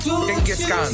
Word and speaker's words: Genghis Khan: Genghis Khan. – Genghis Khan. – Ah, c'est ça. – Oh, c'est bon genre, ça. Genghis Khan. G-E-N-G-H-I-S espace Khan.